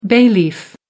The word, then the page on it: bay leaf: American English